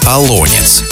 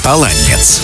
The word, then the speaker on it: Олонец: Нормативным считается ударение в названии на второй слог «Олонец», но допустимое ударение на последний слог «Оланец».